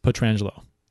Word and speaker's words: Pietrangelo: Yeah, he says he pronounces the family name wrong, so it's actually pronounced Pietrangelo.